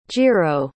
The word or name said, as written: jiro